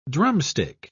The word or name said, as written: drumstick